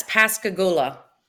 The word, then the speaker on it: Pascagoula: Yeah, it's Pascagoula. But we always refer to it as the Gula.